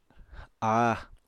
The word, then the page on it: áa: apatani